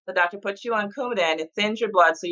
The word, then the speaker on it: Coumadin: I guess for you, the first one was Coumadin. Is that how you pronounce it? – Coumadin, blood thinner.